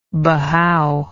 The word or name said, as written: Bahau